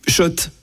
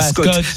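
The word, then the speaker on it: Schott: Oui, Shot et non, pas Scott. Ça fait des années qu'on prononce mal la marque.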